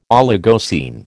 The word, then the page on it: Oligocene: American English